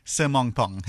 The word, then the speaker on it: Sirmongpong: Sirmongpong.